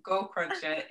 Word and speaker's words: Gaukroger: I just spell it R-A-E. Yeah, it's ear spelled backwards, and Gaukroger is spelled G-A-U-K-R-O-G-E-R, and my company is called Upfront Presenting, and there's a website for that as well.